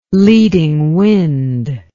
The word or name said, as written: leading wind